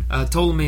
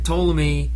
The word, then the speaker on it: Ptolemy: By the way, Ptolemy is spelled P-T-O-L-E-M-Y.